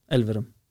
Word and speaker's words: Elverum: Eleverum? – Elverum. – Ja, det är klart att det var fel uttal där.